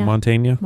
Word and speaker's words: Mantegna: Joe Mantegna.